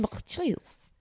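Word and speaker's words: Matthijs: It's spelled Matt H-I-J-S. How would you pronounce that? – Matt H-I-J. Yeah. – Matches. – Matches. – Matches.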